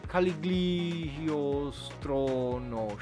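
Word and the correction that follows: Cagliostro: Shiro. Just nici, niciodată n-am știut să îi, uh, să-l pronunț așa, Lupin the Third, The Castle of Caligostro, uh, Caliostro, așa, Caliostro, bine, bine am zis, care e obviously scris, regizat de Hayao Miyazaki.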